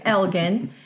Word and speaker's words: Elgin: So again, one of those places in Texas that if you read it, you might pronounce it one way and you'd be wrong. It's Elgin.